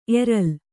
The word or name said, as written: ಎರಲ್